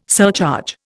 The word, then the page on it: surcharge: Indian